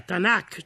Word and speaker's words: Tanakh: Quindi si mette poi in mezzo una vocale per rendere pronunciabili le tre consonanti, e gli ebrei chiamano la Bibbia Tanakh, cioè la Bibbia è Torah, Nevi'im, Ketuvim, legge, profeti e scritti santi.